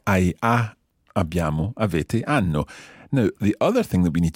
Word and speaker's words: hanno: In Italian, we don't pronounce H. – Of course. – It, but it's mute. So instead of hanno, it would be hanno. – Brava, sì, molto bene, hanno.